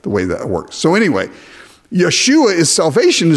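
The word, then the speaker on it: Yeshua (ישוע): Well, Yeshua is Joshua, but you don't pronounce the J in Hebrew. So Jesus' name in Hebrew is Yeshua or Joshua or Yahshua, if you would prefer. In Hebrew, it's Yeshua.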